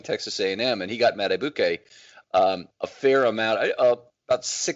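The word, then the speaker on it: Madubuike: Accent on the first syllable, Madibuke.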